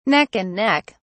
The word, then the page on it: neck and neck: British English